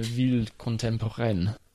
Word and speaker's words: ville contemporaine: It's a ville contemporaine or something. Why don't you pronounce it properly? – Une ville contemporaine.